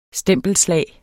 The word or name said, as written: stempelslag